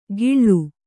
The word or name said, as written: ಕಿಳ್ಳು